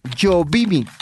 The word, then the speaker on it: Yobimi: Yovimi con B de burro.